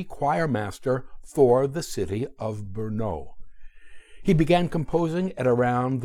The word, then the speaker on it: Brno: At 11, he received a scholarship to attend the Queen's Monastery and School in the city of Brno, B-R-N-O, pronounced Brno, the largest city in Moravia.